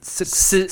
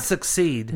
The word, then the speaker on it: secede: Secede. – Secede. I said it right. – You had it right. Yeah. Secede. – Not succeed.